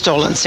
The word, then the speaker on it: Dolenz: Is that how you pronounce it? – Yes, Dolenz. Yeah.